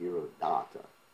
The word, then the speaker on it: Dhiradatta: You have to pronounce it with a long A, Dhiradatta.